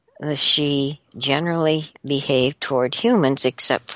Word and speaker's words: sidhe: According to those who speak the Gaelic tongue of Scotland and Ireland, the wee folk prefer to be known as S-I-D-H-E, also spelled S-I-D-H, S-I-T-H, S-I-T-H-C-H-E, and pronounced she, S-H-E-E.